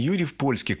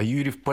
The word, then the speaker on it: Юрьев-Польский: Кстати, правильное название не Юрьев-Польский, к Польше он не имеет никакого отношения, а Юрьев-Польский. Польской. Есть два разных вида произношения. Еще раз говорю, к Польше никакого отношения не имеет.